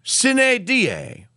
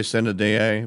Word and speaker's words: sine die: That's what it means literally, sine die. But some correctors are going to go, oh, that's not what it means. You didn't even pronounce it correctly. Sine die, until an unspecified day.